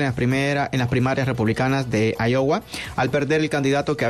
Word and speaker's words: Iowa: ¿Así se pronuncia? ¿Iowa? – Iowa. Ajá.